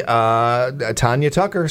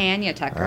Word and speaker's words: Tanya Tucker: Tanya Tucker, 64. – Oh, wow. Doesn't she pronounce it Tanya? – Oh, maybe. You might be right. Yeah, I apologize, Miss Tucker. – It's Tanya Tucker.